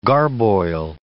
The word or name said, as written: garboil